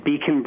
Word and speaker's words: BKON: The website is Beacon Brew, BKONBREW. And we just spelled it a little bit differently, but it's pronounced as in beacon, like a beacon of light, signal, a pathfinding illuminator.